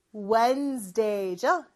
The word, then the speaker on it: Wednesday: 수요일은 Wednesday가 아니고 Wednesday죠. 그래서 D 발음은 생략된다라고 보시면 돼요.